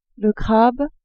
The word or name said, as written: crabe